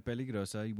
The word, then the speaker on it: Peligrosa: Peligrosa. – And I say it correctly by rolling the R's. Otherwise, you're not legit. You can't get in if you don't pronounce it correct.